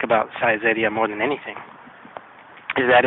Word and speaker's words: Saizeriya: But the way I remember how to say it is that it sounds a lot like size area. So, size area.